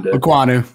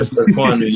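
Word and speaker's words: Ekwonu: Aquanu. Yep. – Yeah. Aquanu.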